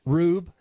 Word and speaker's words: Rube: Rube. Just like you would, you know, if you called somebody a Rube. Like R-U-B-E?